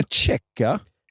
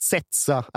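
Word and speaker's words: Ceca: Ja, men du menar Tjecka? – Jag tror att Zeca är det korrekta uttalet.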